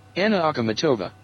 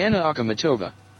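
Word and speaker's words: Anna Akhmatova (Анна Ахматова): Anna Akhmatova. – ¿Cómo? A ver. – Sí. – Anna Akhmatova.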